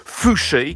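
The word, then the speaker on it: Foushée: L'air qu'on vient d'entendre est signé par une chanteuse qui signe tout simplement de son nom de famille, Fouché, qui s'écrit F-O-U-S-H-E-E, avec étrangement l'accent aigu sur le dernier E, celui de la fin, quoi.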